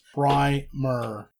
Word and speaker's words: primer: That is the American pronunciation. The British pronunciation is primer.